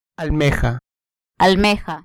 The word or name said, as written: almeja